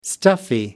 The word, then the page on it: stuffy: American English